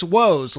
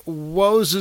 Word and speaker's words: Woes: and he says it's Woes, like Woes is me.